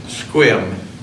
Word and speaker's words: Sequim: of Squim, which is spelled S-E-Q-U-I-M.